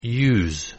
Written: use

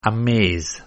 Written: amaze

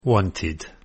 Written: wanted

